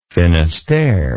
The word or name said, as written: finisterre